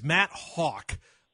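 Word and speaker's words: Haack: Pronounce Hawk. I mispronounced it. It's Matt Hawk, the punter that is coming into the Browns practice squad.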